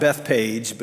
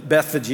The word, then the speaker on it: Bethphage: And in this text, he mentions another little village called, we say in the South, Bethpage, but that is a very Georgia way of saying that. Bethphage is probably the more appropriate pronunciation of that.